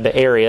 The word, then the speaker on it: Be'eri: He is the son of Be'eri. That's how you pronounce that, Be'eri.